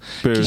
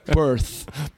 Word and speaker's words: Perth: Perth. Que chique. – Fala de novo aí pra gente. – Perth. Perth.